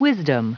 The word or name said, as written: wisdom